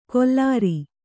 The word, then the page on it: ಕೊಲ್ಲಾರಿ: kollāri